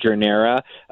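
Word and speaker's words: Journera: Is that Jornera? – That's right, and you are certainly not the only one who has trouble announcing pronouncing startup names. Yeah, this company is called Jornera, a Chicago startup actually founded by the founder of Orbitz, Jeffrey Katz.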